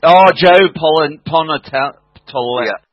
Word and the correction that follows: Pantoliano: Yeah, Pantoliano.